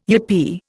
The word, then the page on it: yippee: Indian